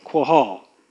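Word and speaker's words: qahal: The Hebrew word, I'm not going to try to pronounce it, it's Q-A-H-A-L, quahal.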